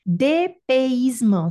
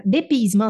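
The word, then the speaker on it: dépaysement: DPismo. Se vocês quiserem, até posso é, posso pegar, é, falar para vocês como é que se escreve. É, DPismo se escreve D E com acento agudo P A Y S E M E N T.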